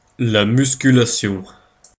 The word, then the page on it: musculation: Paris; [la mys.ky.la.sjɔ̃]